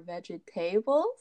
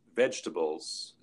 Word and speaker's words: vegetables: The word vegetables is a hard one to pronounce. And we don't, or we want to stress the first syllable, vegetables.